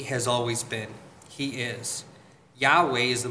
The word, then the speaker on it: Yahweh: The tetragrammaton, the four consonants that make up God's name, are assumed or speculated to be pronounced Yahweh.